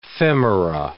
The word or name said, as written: femora